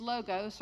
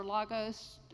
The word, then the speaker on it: logos: So the word, word, is logos or logos, different ways of pronouncing it, in the original Greek.